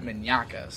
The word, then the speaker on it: Mignacca: Mignacca.